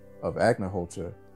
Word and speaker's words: Agnihotra: And I think that an interesting dichotomy in what you were talking about as you described the process of Agnihotra, did I say that right? – Mm-hmm.